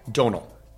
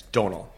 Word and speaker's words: Domhnall: Domhnall. – Domhnall? – Domhnall. – God, I'm going to say you don't pronounce the M. It's Domhnall.